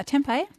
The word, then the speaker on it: tempeh: Is it tempeh? – Yeah, tempeh. – So how is that different to tofu? – So tempeh also is a fermented soybean product, just as tofu is. But with tempeh, the beans are still whole, so it's a brown block and really beautiful.